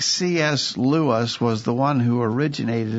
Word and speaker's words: Lewis: Lewis.